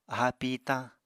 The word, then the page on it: aháa pìitañ: Apatani